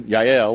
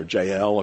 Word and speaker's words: Jael: This woman, Yael, or Jael, if you want to pronounce her name that way, will nail the problem to the ground.